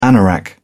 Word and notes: anorak: British English

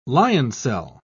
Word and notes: lioncel: British English

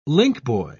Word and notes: linkboy: British English

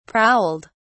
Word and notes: prowled: British English

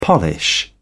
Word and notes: polish: British English

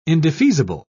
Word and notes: indefeasible: British English

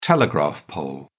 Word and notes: telegraph pole: British English

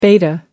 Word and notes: beta: American English

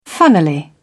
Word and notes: funnily: British English

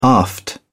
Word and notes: aft: British English

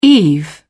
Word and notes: eve: British English

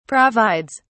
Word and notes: provides: British English

